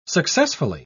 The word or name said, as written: successfully